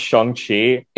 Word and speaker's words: Shang-Chi: I appreciate the conversation, but, you know, it's like me with Shang-Chi, it's actually pronounced Shang-Chi. – I've heard other people pronounce it like that. I say Shang-Chi or Shang-Chi, excuse me. It's Shang-Chi. – So the A is actually an O.